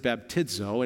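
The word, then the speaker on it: baptizo (βαπτίζω): And in Greek, it looks like this, and you would pronounce it baptizo, and it means to dip or to immerse.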